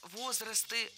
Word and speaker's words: возрасты: Возрастов, возрастам. Все это неверно. Правильно, возрасты, возрастов, возрастом, возрастах. Во всех формах сохраняем ударение на первом слоге.